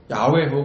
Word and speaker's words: 야훼: 모음의 표기에 따라서 야훼 혹은 여호와를 이렇게 읽게 된 겁니다. 이게 본래 발음이 야훼가 더 의미에 가까운 원문에 가까운 그런 발음에 가까운 것으로 추정됩니다.